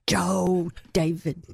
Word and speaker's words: Joe David: Sometimes, if I'm in trouble, she puts a heavy accent on the first. Joe David.